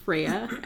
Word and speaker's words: Freya: We got a really awesome tweet from a gal named Freya. I hope I'm saying that right. – Excuse me. – F-R-E-Y-A. – Yes. I'm pretty sure you are.